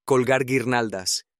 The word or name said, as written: colgar guirnaldas